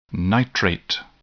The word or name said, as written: nitrate